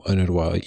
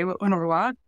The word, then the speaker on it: UNRWA: Yes, so UNRWA, or I tend to pronounce it in the Arabic way, UNRWA, UNRWA in English, and those five letters are UNRWA, and that is the United Nations Relief and Works Agency for Palestine Refugees.